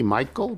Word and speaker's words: Meikle: Like from Barry Mikey? Is that how it's? M-E-I-K-L-E. Michael? Barry Michael? Rhymes with treacle.